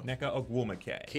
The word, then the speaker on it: Nneka Ogwumike: Um, how, how do you pronounce the, the MVP's name? Yo. Nneka Ogwumike. – ne- got it. Neka got it. Nneka Ogwumike because I can I can never pronounce her name right,